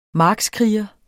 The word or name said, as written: markskriger